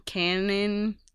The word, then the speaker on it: Kanan: I don't even know how to say his name. Canon. – Kanan, the last Padawan.